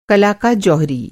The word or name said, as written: जौहरी